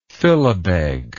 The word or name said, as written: philabeg